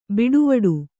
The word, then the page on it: ಬಟ್ವಾಡೆ: baṭvāḍe